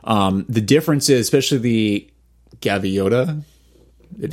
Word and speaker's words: Gaviota: and then probably the Hoka Gavoida. Am I saying that right? I have no idea. – Gavoida? – Is that a... – Gaviota. – Gaviota.